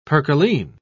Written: percaline

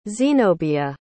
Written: xenobia

